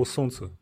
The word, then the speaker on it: солнце: как пишется слово «солнце» на русском языке в русском языке довольно мало слов, которые настолько не похожи пишутся, как, как произносятся, но вот «солнце», блин, это одно из них.